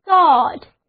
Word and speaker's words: thought: 那么我们的想法就是思考得出的结论，就是 thought, T-H-O-U-G-H-T, thought, 字母组合 O-U 发作 O 的长音 ,G-H 不发音。单词就读作 thought。